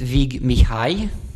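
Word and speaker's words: Vig Mihály: Und du hast, ich werde es natürlich falsch aussprechen, aber du kannst mich korrigieren, Vic Mihaly mitgebracht. Ist das richtig ausgesprochen? – Fast. Ja, Ungarisch ist nicht die leichteste Sprache. Definitiv. In diesem Sinne, in keinem Sinne eigentlich. Ja, er ist Vig Mihaly.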